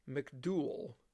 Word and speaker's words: McDouall: So the Indians from Prairie du Chien turned to a lieutenant colonel by the name of Robert McDowell. And his last name is spelled M-C-D-O-U-A-L-L, McDowell.